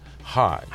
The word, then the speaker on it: Hajj: Hajj.